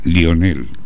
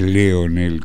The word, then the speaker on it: Lionel: Primero, felicitarte por la pronunciación del nombre de Messi, que es Lionel. No es Leonel, como le dicen todos.